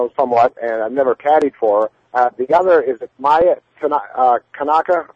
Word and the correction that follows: Ayaka Kaneko: Is that how you pronounce her last name? – Ayaka Kaneko.